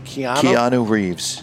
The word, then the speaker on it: Keanu Reeves: Keanu Reeves.